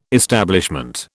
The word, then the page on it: establishment: British English